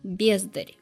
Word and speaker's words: бездарь: Правда, в этом стихотворении оно использовалось в женском роде и с ударением на «а», обнаглевшая бездарь как бездарность. У поэта это слово имело собирательное значение, как пошлая, бездарная, серая толпа. А современное слово, которое мы с вами уже вполне хорошо знаем, имеет ударение на первом слоге и обозначает отдельного человека, лишенного творческого дара, но при этом претендующего на звание творца, поэта, художника, артиста.